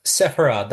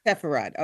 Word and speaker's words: Sepharad: Is it like Sephardic, Sepharad? How do you say it? – Sepharad. That's correct. – Sepharad.